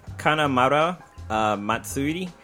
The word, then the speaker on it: Kanamara Matsuri: It's called a Kanamara Matsuri, and if you translate that to English, it means Festival of Steel Phallus.